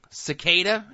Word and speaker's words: Cicada: And we get a little bit of information about Cicada. Is that how they're pronouncing it on the show? – Yes, that's how his name is pronounced. Like okay. The insect, I guess? – Right, after an insect.